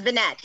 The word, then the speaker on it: Vinett: It's my husband's name so it's Vinett. He's not French.